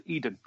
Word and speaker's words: Aedem: okay, it's a homebrew world of mine called Eden. It's spelled A-E-D-E-M. So Eden with an A in front.